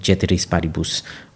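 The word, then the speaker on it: ceteris paribus: ceteris which is that all all other things remain same which is technically pronounced Chetiris Paribus.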